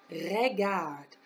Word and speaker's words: regard: It is regard. R-E-G-A-R-D-E. Regard. It's kind of a rolled R in there if we want to practice how to say it. Regard. The next one we have is maman. M-A-M-A-N.